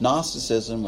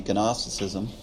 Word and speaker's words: Gnosticism: And in the time that John was writing, there was a form of false teaching that was called Gnosticism, or some pronounce the G, Gnosticism, that said that salvation was in a secret knowledge.